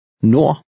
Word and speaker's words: nord: The final D is silent. – Nord.